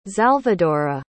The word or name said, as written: xalvadora